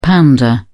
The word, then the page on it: pounder: British English